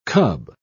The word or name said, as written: cub